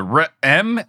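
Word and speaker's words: Re'em: The next creature is the R-M, and yes, I'm pronouncing that with an apostrophe in the middle of it. R-E apostrophe E-M, 4X rating.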